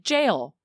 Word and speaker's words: gaol: And when I looked up the pronunciation, it just said jail. Like, do people spell jail like that in Canada? – Did you say J-A-O-L or G? – G-A-O-L.